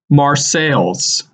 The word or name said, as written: Marseilles